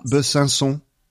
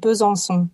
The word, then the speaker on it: Besançon: Besançon. – Besançon? – Not bad, but Besançon.